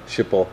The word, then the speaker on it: Schiphol: In, I don't know if I'm pronouncing it correctly, but is it Schiphol in Amsterdam? – Oh, yeah. Schiphol.